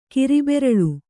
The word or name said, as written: ಕಿರಿಬೆರಳು